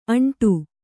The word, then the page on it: ಆಂಡು: kannada